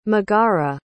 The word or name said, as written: megara